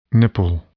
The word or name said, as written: nipple